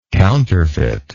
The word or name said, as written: counterfeitness